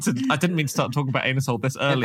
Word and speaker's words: Anusol: I believe it's pronounced Anusol. – Yeah, is it? Heck, it's so clearly no, Anusol. – The company says it's pronounced Anusol.